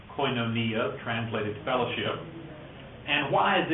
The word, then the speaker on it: koinonia: It's pronounced koinonia. Sometimes people will use that term, koinonia.